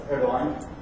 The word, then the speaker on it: Erdogan: So this is, again, a silent G, that's why it's Erdogan.